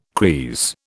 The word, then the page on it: creese: British English